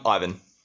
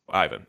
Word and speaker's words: Ivan: Okay, so how how do you say it? Um, Ivan. Ivan. Okay, then you'll be Ivan.